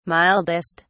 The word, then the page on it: mildest: British English